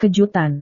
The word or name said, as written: kejutan